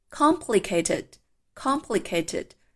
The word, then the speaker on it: complicated: Complicated，complicated，注意这个词的重音在前面第一音节。